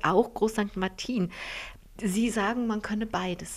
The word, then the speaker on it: Groß St. Martin: Sie sagen Groß St. Martin und ich musste mir das mühsam angewöhnen, Groß St. Martin zu sagen, mit der Betonung auf dem I.